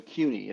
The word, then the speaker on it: Kueny: Oh my Q oh it's French, so CUNY. It's you kind of pronounce it with a Q, really, to the K, but it's CUNY.